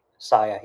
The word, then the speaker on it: Sia: How do you spell that? – S-I-A. – Saya. – S-I-A. Saya, yep.